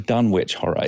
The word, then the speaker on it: Dunwich: But anyway, we pronounce it Dunwich, or at least the town is pronounced Dunwich. But the Dunwich in New England should be pronounced Dunwich, to my mind, because that's how it would be pronounced there. And I find it a bit annoying when I hear someone call it the Dunwich Horror.